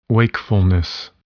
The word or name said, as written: wakefulness